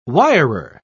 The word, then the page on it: wirer: British English